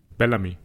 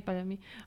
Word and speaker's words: Bellamy: Also ganz am Anfang ist ja, wie spricht man ihn aus, Bell? – Bellamy. – Okay, ah, eh, Bellamy. Okay.